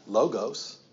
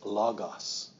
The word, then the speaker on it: logos: It's not logos. That's our English coming at it. It's logos. That letter O in the Greek is more of an A.